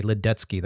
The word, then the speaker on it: Ledecky: And so when I saw that last name, my wife is Czech. So it's like easy for me to then see his name and say Ledecky. That's the way you pronounce it.